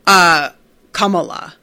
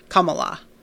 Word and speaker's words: Kamala: Rowan says, Kamala. All the A sounds are pronounced the same way. Okay. Kamala.